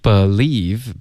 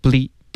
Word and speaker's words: believe: OK Believe 别发成 ble OK 这是一个音节我们要 believe 两个音节